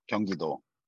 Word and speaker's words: Gyeonggi-do: like Gyeonggi province. Is that right? – Yeah. If you're saying in English, Gyeonggi province. If you're saying in Korean, Gyeonggi-do.